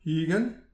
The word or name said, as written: higenn